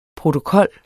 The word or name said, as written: protokol